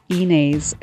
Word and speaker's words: Inez: Well, a surname of Murphy, which is Irish, but they gave me a Spanish name, which is Inez. It's spelled I-N-E-Z.